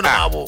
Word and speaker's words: Anomabo: No, no, Anomabo. – Say Anomabo. Yeah, you pronounce it Anomabo.